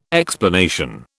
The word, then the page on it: explanation: British English